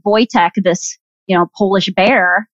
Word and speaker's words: Wojtek: Wojtek is how you pronounce it because it's Polish, right? Okay. So like I said, it looks like it would be like Wojtek, but it's apparently pronounced Wojtek.